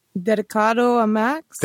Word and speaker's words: Dedicado a Max: Today, we're going to be talking about Better Call Saul Season 5, Episode 5, Dedication a Max. Did I pronounce that correctly? What do you think? – Dedicado a Max? – There you go. See, you did it better than I did.